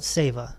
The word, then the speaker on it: seva: It's called SEVA. They call it SEVA. S-E-V-A. The E's are pronounced like A.